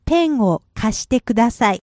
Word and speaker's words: ペンを貸してください: The correct way to say it, and if you want to try it, is... – ペンを貸してください。